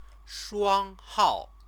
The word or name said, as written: shuanghao